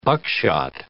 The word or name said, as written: buckshot